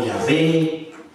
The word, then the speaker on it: Jehová: No hay cómo, entonces tenemos que poner vocales ahí, imaginar cómo sería. Y ahí entonces bueno, pusieron Jehová, Yahova o Yahvé, distintos nombres.